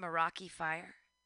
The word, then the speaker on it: Meraki: So is that how you pronounce it, Meraki Fire? – That is exactly how you pronounce it.